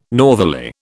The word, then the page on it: northerly: British English